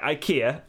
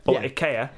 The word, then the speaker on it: IKEA: IKEA, IKEA, as it's pronounced. – That is how it's pronounced.